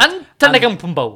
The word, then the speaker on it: Antetokounmpo: No, so you pronounce the N before the M. So Antetokounmpo.